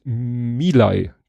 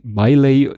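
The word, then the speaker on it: Milei: Gut, kommen wir zu den gesammelten Werken von Ed Compot, die ich wie immer ein bisschen kuratiert habe, weil wie gesagt, dass ich Sachen falsch ausspreche, ist ein Running Gag. Äh, ich habe es mir gemerkt. Milay, der argentinische Präsident heißt, ich spreche den, glaube ich, immer, ich versuche da immer irgendwas, äh, Miley, nein, Milay.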